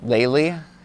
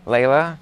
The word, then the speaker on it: Layla: From there, I go to this Layla box, which I just found out isn't pronounced Laylee. I was in contact with them, and they wrote me, fun fact, Layla is actually pronounced like how Americans say tequila. – Oh. – Layla.